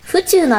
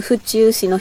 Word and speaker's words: 府中: 府中のアクセントは宇宙じゃなくて地球の方。